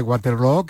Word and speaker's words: Waterloo: Pues no lo es, desde luego, pero Ava ha hecho mucho daño en la pronunciación de Waterloo, que se pronuncia igual que Feijóo.